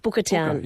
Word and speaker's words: Bookertown: But before that, I'd like to play You and Me, because this is written apparently in Buckertown. Tell me about this. I said that correctly, didn't I? – Almost. Booker. – Oh, Booker. Sorry, like bookend. Yeah. Booker. Bookertown.